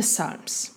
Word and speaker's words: psalm: But before that, what are the Psalms? Of course, the Psalms are very old songs of the Old Testament. Literally, they were songs because the word psalm, which is pronounced with this, you know, silent P, comes from the Greek word psalmos, song sung to a harp.